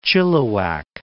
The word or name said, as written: Chilliwack